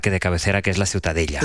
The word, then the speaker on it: Ciutadella: La Ciutadella. – Ciutadella.